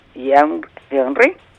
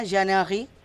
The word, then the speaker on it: Jean-Henri: ¿cómo se dice allí? Jean-Henri. – Ajá, Jean-Henri.